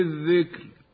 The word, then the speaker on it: الذكر: ولعلنا لاحظنا النطق بالراء من كلمة الذكر بأنها مرققة وليس ترقيقها لأنها مكسورة في الأصل، بل لأنها ساكنة بعد كسر مفصول بساكن غير حصين، فهي ساكنة بعد الذال والفاصل الكاف الساكنة، وهو حرف غير حصين لأنه ليس حرف استعلاء كما في كلمة مصر وفي كلمة القطر عند الوقف عليهما.